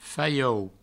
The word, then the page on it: faiou: Provençal